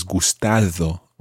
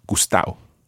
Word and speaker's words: gustado: But if you try to just understand that, that the gustao is the same as gustado, okay? And the D sound in there is the way it's written and often it's pronounced as gustao.